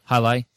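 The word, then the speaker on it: Jai Alai: Let's see, there was a disc, though the Jalali. Is that how you say it? – Hi-Li. – Right. I said Jakari earlier. Sorry.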